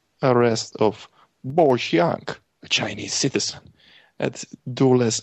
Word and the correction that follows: Dulles: Airport in, okay, Dulles Airport. Gotta get my American pronunciation there.